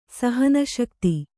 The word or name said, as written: ಸಹನಶಕ್ತಿ